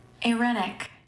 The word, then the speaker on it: irenic: I-R-E-N-I-C. But in the little pronunciation, the I is A-Y-E. Erenic. – Yeah, it sounded like they were saying Erenic. – Erenic. – So it's I, but it's pronounced A-R-E.